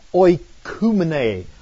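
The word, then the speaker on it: oikoumene (οἰκουμένη): Now, it's interesting that word world is a word which is pronounced in Greek, oikoumene. Oikoumene.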